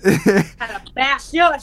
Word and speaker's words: Calabasas: I think they, for the most part, live in like the Calabasas house. Calabasas? Is it like Cassius house? Is it pronounced like Cassius? I'm not. – Calabasas.